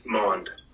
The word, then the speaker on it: Maund: Maund.